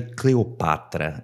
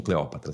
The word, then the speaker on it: Cleopatra: Na verdade, Cleopatra é, é, é a pronúncia correta, mas todo mundo conhece como Cleópatra, vocês entenderam.